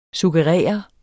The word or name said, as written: SUGGERERE